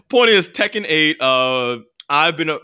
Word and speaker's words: Tekken 8: Tekken 8. A new challenger appears. – That's the correct pronunciation. – I mean, that's just how the crazy, like, ridiculous Japanese announcer says it.